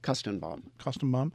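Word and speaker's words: Koestenbaum: Kostenbaum.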